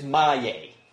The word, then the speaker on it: Maillet: I said, okay, this way, M-Y, my. Okay, one word, my. The next word, Y-A-Y, my, yay. And they're like, oh. Because in the way it's spelled, right, M-A-I-L-L-E-T, it's so easy to say, mail it?